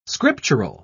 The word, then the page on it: scriptural: British English